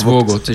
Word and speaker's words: Vogt: Vogt Vogd? Vogt? – Eh, ja, Vågot, ikke det? Vågot det uttales Oh, ja, er det det?